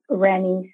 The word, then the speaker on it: Renny: It's Renny C. I always tell people it's like Jenny with an R.